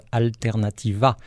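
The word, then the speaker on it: Alternatiba: Voilà, le B pour prononciation V en fait, Alternativa, qui veut dire tout simplement alternative, mais en basque.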